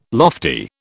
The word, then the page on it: lofty: British English